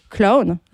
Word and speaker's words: Clown: On vient de passer le titre Clown, je le prononce oui, bien, c'est bien.